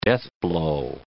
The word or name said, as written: deathblow